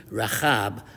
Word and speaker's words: Rahab: And I'm intentionally pronouncing the chet sound in Rahab when referring to the harlot in Joshua in order to note this distinction.